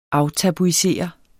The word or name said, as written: aftabuisere